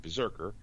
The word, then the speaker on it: BRZRKR: Boom Studios has released more artwork for the Keanu Reeves and Matt Kent's October debuting 12-issue comic series called Berserker. It's spelled BRZRKR.